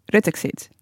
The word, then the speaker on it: Ruttexit: Hij heeft er ook eentje gemaakt over Rutte, waarvan ik nog steeds niet helemaal weet hoe je moet uitspreken, want het is Ruttexit. Ruttexit. Het is zeg maar Rutte en Exit.